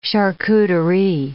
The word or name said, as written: charcuterie